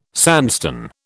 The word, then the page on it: sandstone: British English